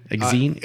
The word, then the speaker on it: Exene: And then next, now, how would you pronounce his name? – This was said on the commentary. It was like Exene. – Exene? Her name used to be Christina, I believe. – Yeah, Exene. It's E-X-E-N-E Cervenka.